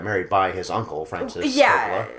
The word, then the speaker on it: Coppola: Coppola, I always say it wrong. Coppola, right? – Coppola.